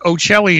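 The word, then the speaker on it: Ochelli: Well, look, Ocelli is all you got to know, which is my last name.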